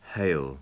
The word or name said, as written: hail